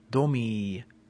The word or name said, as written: domi